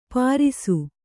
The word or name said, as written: ಪರಿಸು